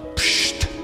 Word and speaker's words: psht: And of course, we have the wonderful word which has no vowel in it, which is pronounced psht, which means you are dead to me.